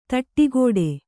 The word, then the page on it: ಥಟ್ಟುಗೆಡು: Kannada